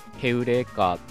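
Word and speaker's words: ヘ ウ レ カ ー (εὕρηκα): ました深夜 15 の壺皆さんいかがお過ごしでしょうか皆さんはアルキメデスがヘウレカーで叫んだっていうねそういった逸話ご存知でしょうか、まあ、古代ギリシャ語の発音はヘウレカーみたいな感じだったらしいんですけど日本語の表記だとエウレカとかあるいはユリイカみたいに、まあ、そういった風に書かれることもあります。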